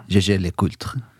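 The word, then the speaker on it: Jaeger-LeCoultre: Jag fick den stora äran att närvara på Jäger Le Coultre. Eller Jägers. – Är det som man uttalar det? – Jäger Le Coultre. – Ja, det lät ju nog rätt bra.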